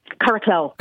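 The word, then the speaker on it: Curracloe: Curraghclough. – Curraghclough, right.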